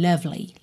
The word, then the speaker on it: lovely: É lovely, a gente tem um E pintado de cinza, ele não vai ser pronunciado, tá? Então, love, separa no V, já vai pro L, lovely, lovely, tá?